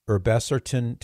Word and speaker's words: Irbesartan: The FDA this past week announced that Lupin Pharmaceuticals is recalling batches of its Irbesartan tablets, and it's spelled I-R-B-E-S-A-R-T-A-N, Irbesartan, and Irbesartan and hydrochlorothiazide tablets.